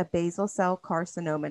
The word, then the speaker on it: basal cell carcinoma: So I found out on Monday that my mom has a basal cell carcinoma.